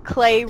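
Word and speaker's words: Klei: And it's on their website, it says Clay rhymes with play.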